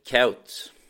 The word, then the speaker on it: Kuyt: Men det, stava, det, det uttalas egentligen K-E-U-T. Så att det, han heter egentligen Dirk Kaut.